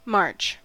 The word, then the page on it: march: American English